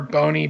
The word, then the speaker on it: Boney: It is at underscore underscore Boney, B-O-N-E-Y.